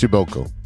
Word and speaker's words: Przybylko: it's spelled P R Z Y B Y L K O. But it's it's pronounced Shiboko.